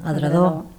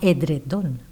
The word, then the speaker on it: edredor: però a més és llevar-li la N, edredor, edredor, i no edredon, perquè també la pronúncia també és important tenir-la en compte.